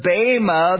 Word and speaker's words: bema: It's spelled bema, B-E-M-A, but it's pronounced bema.